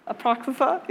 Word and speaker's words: Apocrypha: The Apocrypha, I believe is how you say that word. Apocrypha, yeah.